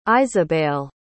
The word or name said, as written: iseabail